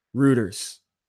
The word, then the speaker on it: Reuters: Reuters.